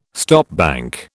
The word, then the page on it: stopbank: British English